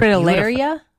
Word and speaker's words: fritillaria: And then Lucy texted us in saying, good morning, my sweet fritillaria. How do you say that? Oh my gosh, they're fritillaria? – Fritillaria.